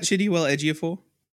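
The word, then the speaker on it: Chiwetel Ejiofor: You're talking about Chidiwell Ejiofor? – Yeah.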